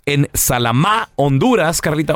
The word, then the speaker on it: Salamá: Con acento en la última A, es una... Salamá, Honduras.